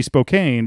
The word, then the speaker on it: Spokane: And it's also, it should be Spokane because A and E should be the pronounced word.